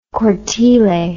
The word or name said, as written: cortile